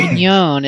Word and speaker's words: Mignone: Mignone. It's like a filet mignon. It's got E on the end.